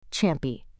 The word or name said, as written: Champy